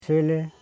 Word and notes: shele: she.le; Koro

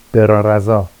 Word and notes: berɑrazɑ: Vafsi

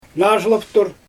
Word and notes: naazhylaptur: Xyzyl

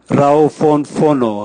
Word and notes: rauvonvono: Raga

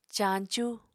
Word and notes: caañcú: apatani

